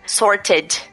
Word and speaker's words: sorted: Você fala o E porque você é obrigado. Você não consegue falar sorted. Sorted. – É verdade não dá. É, é, muito esquisito. Então você é obrigado a pronunciar o E.